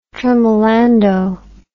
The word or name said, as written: tremolando